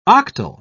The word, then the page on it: octal: British English